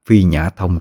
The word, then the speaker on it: Phi Nhã Thông: Đọc là Phi Mã Thông, nhưng xin đính chính lại, đó là Phi Nhã Thông thì mới đúng.